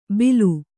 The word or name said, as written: ಬಿಲು